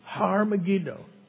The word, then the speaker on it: Har Megiddo: The word for mountain and just mountain is Har in Hebrew. Har Megiddo. And people mistranslate that, mispronounce it as Armageddon.